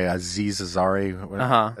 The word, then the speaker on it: Aziz Ansari: is constantly being made fun of by Aziz Ansari.